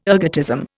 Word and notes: ergotism: Indian

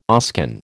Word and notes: Oscan: American English

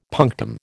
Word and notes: punctum: American English

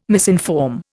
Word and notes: misinform: Indian

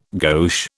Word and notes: gauche: British English

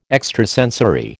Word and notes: extrasensory: American English

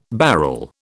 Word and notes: barrel: British English

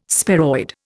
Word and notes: sparoid: Indian

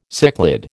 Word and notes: cichlid: American English

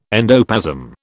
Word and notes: endoplasm: British English

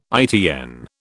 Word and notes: ITN: British English

